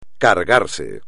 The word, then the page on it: cargarse: espagnol